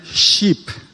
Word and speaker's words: sheep: 양은 sheep이야. 아빠는 지금 양을 sheep. 그거는 배야, 배.